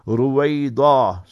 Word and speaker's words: Ruwaydha: Ruwayda, R-U-W-A-Y-D-H-A. So that D-H-A will highlight the dwad. Ruwaida.